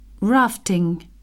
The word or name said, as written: rafting